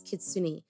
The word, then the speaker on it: Kitsune: So I believe the right way to say it, the way that we were told, is Kitsune.